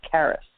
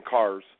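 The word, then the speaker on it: Carys: Her first name is Welsh, and it's pronounced Karis. – Karis. Okay. Because I was just saying Cars. I was just saying Cars Jones. So Karis. Okay.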